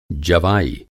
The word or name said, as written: जवाई